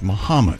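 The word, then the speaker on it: Mahomet: You should get Tom to pronounce Muhammad, Illinois, correctly. It's Muhammad.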